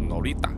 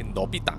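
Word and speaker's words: Noritta: 而死者呢，就是一位非常漂亮的马来姑娘，叫做啊 Norita Samsudin。呃，以下呢，我就会称呼这位女死者的名字叫做 Norita。呃，另外呢，在研究这个案件的时候，也常常会把她的名字呢误读成为 Nobita。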